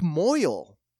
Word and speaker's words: mohel: So they go on to describe his part in Seinfeld, and he played the, and just I love the title, Slapstick Moyle in the Briss episode. – I am dumb. I could not pronounce the word the way it was spelled. I think it's spelled M-O-H-E-L.